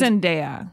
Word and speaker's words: Zendaya: This is the newest one with Zendaya or Zendaya. I'm not sure how you actually say her name. And it's Zendaya.